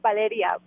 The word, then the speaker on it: Valeria: Hi, yes, Valera. – It's actually pronounced Valeria, but I won't have you pronounce it like that.